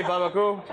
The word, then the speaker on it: Barbancourt: well, we pronounce it Barbancourt. Oh they, they say Barbancourt.